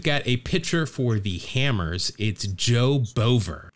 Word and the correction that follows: Boever: B-O-E-V-E-R? – I believe it's Baver. – He should really spell it with an A if he wants that to be the case. – Baver the Saver? – Yeah, you're correct here. The pronunciation Baver.